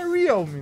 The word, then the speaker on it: Realme: Não é Realme? – É Realme, né? Na verdade.